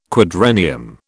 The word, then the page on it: quadrennium: British English